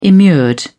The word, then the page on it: immured: British English